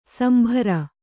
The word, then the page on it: ಶಾಂಬರ: Kannada